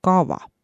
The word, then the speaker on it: kava: Horvaat ütleb V-ga, kava.